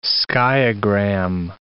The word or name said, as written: skiagram